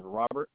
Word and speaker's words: Robert: First name is Robert, R-O-B. E-R-T, Gamble, that's G-A-M-B-L-E, just like gambling.